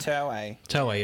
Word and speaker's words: Toei: So, in the late 70s, Wikipedia tells us that the Toei Company, I'm not sure how to pronounce that. – Toei, is it? – Yeah, they make Dragon Ball.